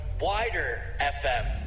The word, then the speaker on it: WIDR: It's actually pronounced 89.1 Wider FM. – That's what I say. Wider FM.